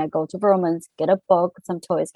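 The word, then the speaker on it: Vroman's: It's spelled V-R-O-M-A-N apostrophe S, Burlman's.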